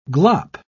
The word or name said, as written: glop